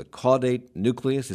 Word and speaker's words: caudate nucleus: The caudate nucleus, is that how you say it? – That's how you say it.